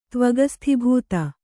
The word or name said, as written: ತ್ವಗಸ್ಥೀಭೂತ